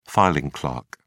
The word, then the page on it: filing clerk: British English